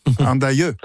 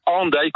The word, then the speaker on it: Hendaye: Et d'ailleurs, Andaille s'écrit aujourd'hui H-E-N, donc peut-être qu'en français on dirait plus facilement Andaille. – Ah oui. – Mais c'est bien Andaille qu'on dit. On a bien conservé le son ou la prononciation qui était celle de l'orthographe du XVIe siècle.